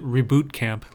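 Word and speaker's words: Re-Boot Camp: I think they want you to pronounce it Reboot Camp, like boot camp.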